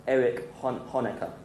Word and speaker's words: Honecker: Erich Honecker. That's how you pronounce it.